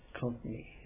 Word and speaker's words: Konkani: How do you say it? – Konkani.